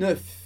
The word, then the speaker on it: œuf: Quando a gente tem um só, um ovo, a gente fala un œuf, un œuf, pronunciando o F.